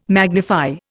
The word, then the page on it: magnify: Indian